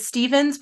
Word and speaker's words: Stephens: Well, you can connect with me on LinkedIn, Elizabeth Stephens with a PH.